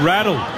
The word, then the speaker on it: Rattle: It's John Rattle. – Rattle? – Rattle like saddle.